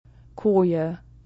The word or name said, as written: Koje